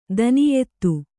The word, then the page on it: ಧನ್ಯತೆ: dhanyate